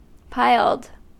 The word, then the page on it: piled: American English